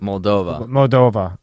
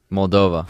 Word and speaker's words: Moldova: Yeah, I think he's going to go Ukraine, Moldova. Am I saying that right? – Moldova.